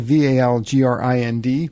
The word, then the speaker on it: Valgrind: And I look at it, it's spelled Valgrind. – Mm-hmm. – And you say it like grinned as in smiling. And so I have been mispronouncing that my entire Valgrind using career.